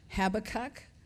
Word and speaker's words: Habakkuk: He was a prophet with a burden for God's people, and the pronouncer says that you say it, Habakkuk.